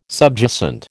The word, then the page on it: subjacent: American English